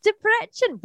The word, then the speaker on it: Debrecen: So I will try my Debrecen. – Yes, Debrecen.